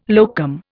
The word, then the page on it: locum: Indian